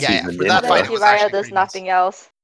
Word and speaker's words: Jiraiya: Like, I brought Jiraiya, like, I don't know how to pronounce that. – Jiraiya, yeah. – Oh, go again. – Again? – Jiraiya? – Yes, that's the dude.